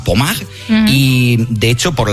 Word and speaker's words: Pommard: Se escribe Pommard, como si fuera en castellano, pero Pommard, pues allí en Francia, hablamos de unas imágenes.